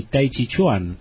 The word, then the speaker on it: Tai Chi Chuan: como el Qigong y Tai Chi Chuan.